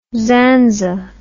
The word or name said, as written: zanza